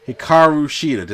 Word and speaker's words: Hikaru Shida: We get the Women of 1,000 Holds, Serena Deeb taking on Hikaru Shida.